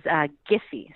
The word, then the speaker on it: Giphy: It is actually still up for debate, but the official pronunciation is Giphy, so it's a hard G.